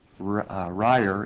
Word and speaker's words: Rayer: 17177, on behalf of Pauline Rayer. It's pronounced Rye like in rye bread.